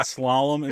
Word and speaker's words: slalom: Like, I get that slalom is a ski term, but even now I don't know what it means. And as a kid, I couldn't even pronounce it. It'd be like, salam. – Slalom.